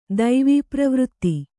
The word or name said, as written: ದೈವೀಪ್ರವೃತ್ತಿ